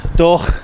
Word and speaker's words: Doagh: Yeah, Doagh. As the locals pronounce it, I think. Or in Belfast, it's Doagh. – Doagh.